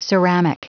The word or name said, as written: ceramic